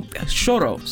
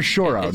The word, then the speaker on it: choro: This year's concert of samba and choro features the instrumental group Agua de Moringa, joined by vocalist Mariana Baltar.